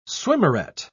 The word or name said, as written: swimmeret